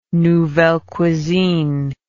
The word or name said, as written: nouvelle cuisine